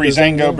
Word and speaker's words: Breezango: Breezango, Breezango.